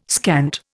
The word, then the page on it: scant: Indian